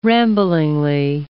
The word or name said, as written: ramblingly